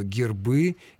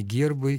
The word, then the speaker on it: гербы: и в формах множественного числа ударение в этом слове переходит на окончание. О гербе, на гербе, множественное число гербы, гербов, а никак не гербы и не гербов.